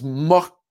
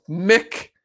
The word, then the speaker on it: Muick: She named the second M-U-I-C-K. Looks like you would pronounce it as muck, muck, but they're claiming that it's pronounced Mick after Loch Mick, a lake on the Balmoral Estate in Scotland.